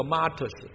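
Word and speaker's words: Martus (μάρτυς): 这个见证文原文呢，希腊文的读音叫 Martus， M-A-R-T-U-S，这个字后来演变成英文的 Martyr 这个字，殉道士这个字，在当代很多的信主人，他信主的时候旧死过变成新的了，成为主的门徒，成为主的工人，成为主的见证人。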